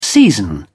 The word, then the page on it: season: British English